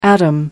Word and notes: atom: American English

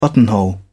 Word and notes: buttonhole: British English

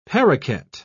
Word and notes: paroquet: British English

